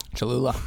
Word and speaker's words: Cholula: And that's where we say buy the Cholula, Did I say it right, Cholula? – Cholula.